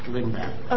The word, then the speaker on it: Lindbach: Is it Lindbach? – Lindbach. – Okay. Just like it's spelled.